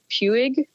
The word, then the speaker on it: Puig: So I got these I don't know how to pronounce it, but it's like Puig, p-u-i-g. – Yeah, I think it's Puig. Yeah.